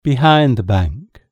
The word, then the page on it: behind the bank: British English